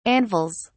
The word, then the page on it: anvils: British English